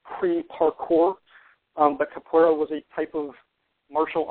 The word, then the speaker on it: Capoeira: It's a Capoeira.